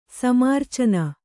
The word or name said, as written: ಸಮ್ಮೂರ್ಛನ